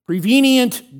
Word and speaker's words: prevenient: It's like convenient, except it's pre-convenient. Grace. I'm not saying it right. I screwed it up. Prevenient grace. There I got it.